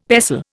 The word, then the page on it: pestle: Indian